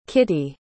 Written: Kittie

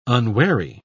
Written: unwary

